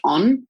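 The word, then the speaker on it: Ohne: So I've been using this CBD body balm from a brand called On. – And mm. – the way it's spelt is very different to the way it's pronounced. So it's O H N E, but it's got like um, it's quite potent CBD.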